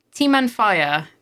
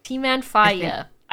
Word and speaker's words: Timanfaya: Timanfaya. – Timanfaya.